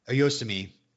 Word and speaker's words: Oyasumi: Oyasumi.